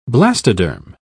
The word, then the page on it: blastoderm: British English